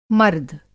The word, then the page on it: मर्द: مرد